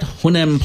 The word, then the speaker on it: Hunnam: Charlie Hunnam. Oh, am I saying that right? Hunnam plays Fawcett.